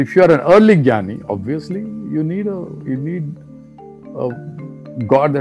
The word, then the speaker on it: Jnani: If you are a Jnani or a J-N-A-N-I, different pronunciations are being used for this, Jnani, Jnani.